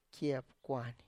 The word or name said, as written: kie pkwany